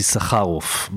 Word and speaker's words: Issacharoff: I know I'm pronouncing it wrong because it has a much better tone. And I'm in his apartment. I'm overlooking a beautiful neighborhood in Tel Aviv. And Avi, tell me how I got your name wrong. Tell me how it's really pronounced and what your background is. – It's Issacharoff, but because I know that you're American, I told you right at the beginning, go for the easier version, which is Issacharoff.